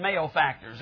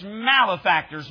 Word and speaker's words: malefactors: And I know a lot of people read that malefactors. That's not how you pronounce it. It's malefactors. Mal simply meaning bad.